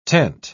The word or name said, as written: tent